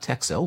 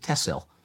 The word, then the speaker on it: Texel: Right, and I'm going to correct you now and say it is not Texel, which is how it's written, yeah. – T-E-X-E-L. They pronounce it Tessel. – Okay. – And the locals will tell you it's because X means SS.